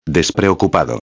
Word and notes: despreocupado: espanhol

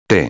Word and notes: te: espanhol

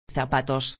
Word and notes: zapatos: espanhol

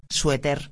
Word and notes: sueter: espanhol

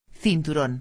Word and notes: cinturon: espanhol

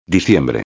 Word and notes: diciembre: espanhol

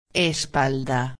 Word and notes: espalda: espanhol

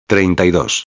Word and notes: 32: espanhol

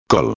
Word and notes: col: espanhol

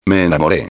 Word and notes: me enamore: espanhol

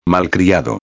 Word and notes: malcriado: espanhol

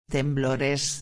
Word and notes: temblores: espanhol

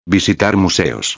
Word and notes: visitar museos: espanhol

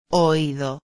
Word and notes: oido: espanhol

